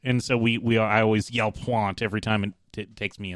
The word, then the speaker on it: Pointe: except it was P-O-I-N-T-E is how it's pronounced. – Perimeter Pointy.